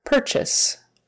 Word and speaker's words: purchase: Again, the UR is pronounced like an ER, er, er, purchase, purchase. Instead of saying purchase or purchase or whatever you might want to call that, it's more like a purchase is, purchase or purchase, either one, it or a.